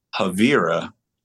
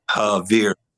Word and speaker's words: Havira: And then, but there's part of Havira that doesn't have the spelling ERA, which I actually think my name is ERA, but it's spelled IRA. So the deep meaning is there's no Vera in Havira.